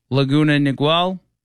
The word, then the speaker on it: Laguna Niguel: Laguna Niguel? – Sounds good to me.